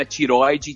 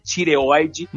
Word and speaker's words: tireoide: Assembleia, tireoide, né? Que não é tiroide, né? Tireoide.